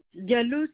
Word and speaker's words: Diallo: Diallo, c'est DIADZLO.